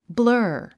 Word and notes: blur: blə’ː r; ブラー

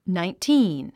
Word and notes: nineteen: nàintíːn; ナインティーン

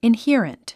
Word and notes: inherent: inhíərənt; インヒアレント

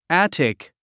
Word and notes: attic: ǽtik; アァティク

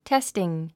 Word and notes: testing: téstiŋ; テスティン

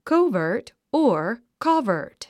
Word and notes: covert: kóuvərt; コゥバート

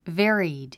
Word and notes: varied: vé ə rid; ヴェアリド

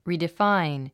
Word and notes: redefine: リディファイン

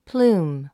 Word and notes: plume: plúːm; プルーム

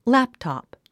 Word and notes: laptop: lǽptɑ̀p; ラプタプ

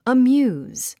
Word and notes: amuse: əmjúːz; アミューズ

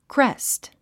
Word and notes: crest: krést; クレェスト

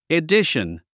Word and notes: edition: idíʃən; イディション

